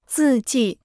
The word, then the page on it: 字迹: zì jì